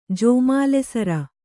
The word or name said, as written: ಜೋಮಾಲೆಸರ